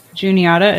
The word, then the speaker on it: Juniata: I don't know why I keep Juniata. Is that how you pronounce the name? – Yeah.